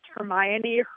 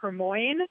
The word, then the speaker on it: Hermione: sort of like the way I used to pronounce Hermione, Hermoine, until the Harry Potter movies came out.